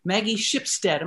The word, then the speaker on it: Maggie Shipstead: And then, as we were talking earlier, we are back next week and we've got Maggie Shipstead. Am I pronouncing that correctly, Ron? – Yes.